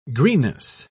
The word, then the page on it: greenness: British English